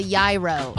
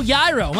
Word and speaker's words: gyro: It involves a gyro or a gyro, however you call it. – It's a gyro. – Oh, gyro. My bad. I always say that wrong.